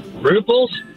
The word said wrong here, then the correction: rubles: I don't know. – Close. Rubles.